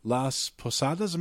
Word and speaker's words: Las Posadas: And we go into this almost storyboard type presentation of Las Posadas. Am I saying that correctly? – Yeah. I believe so, yeah.